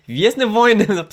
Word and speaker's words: Gwiezdne Wojny: Auch, Gwiezdne Wojny. – Jetzt hast du es richtig ausgesprochen.